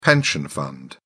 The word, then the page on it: pension fund: British English